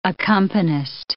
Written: accompanist

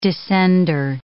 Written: descender

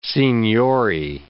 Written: signori

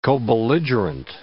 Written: cobelligerent

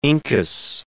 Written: incus

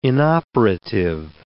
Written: inoperative

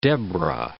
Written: Deborah

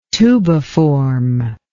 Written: tubiform

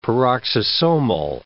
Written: peroxisomal

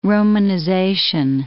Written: romanization

